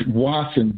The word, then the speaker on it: Wasson: It's Wasson, W-A-S-S-O-N. It's two S's instead of a T.